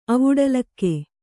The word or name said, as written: ಅವುಡಲಿಕೆ